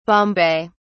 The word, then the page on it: bombay: British English